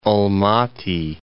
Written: Almaty

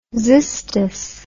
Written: xystos

